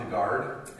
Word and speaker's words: DeGard: But my name is Ron DeGard, and I tell people I have to say my name slowly, because if I say it fast, it sounds like Rhonda Gard.